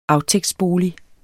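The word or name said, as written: AFTÆGTSBOLIG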